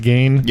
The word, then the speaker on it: Gein: But, Ed Gein, I think it's G-E-I-N is how you spell it, Ed Gein.